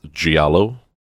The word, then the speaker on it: giallo: I think it's Giallo, is the pronunciation. Italian really throws me for a loop because I want to pronounce things like they would be pronounced in Spanish.